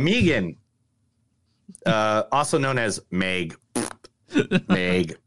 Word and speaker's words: M3GAN: It's Megan, but I'm calling it M3GAN because that's what it says.